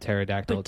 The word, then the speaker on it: pterodactyl: I bet you pterodactyl wings would taste amazing. – Remember, the P is silent in pterodactyl. – P-T-E-R-A.